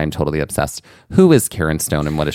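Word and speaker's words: Cairn Stone: This is spelled C-A-I-R-N, and it sounds like a woman's name.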